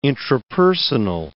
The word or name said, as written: intrapersonal